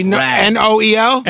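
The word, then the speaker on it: Nowell: Noel. – Bradley N O E L N O W E L L. – No, Bradley Noel.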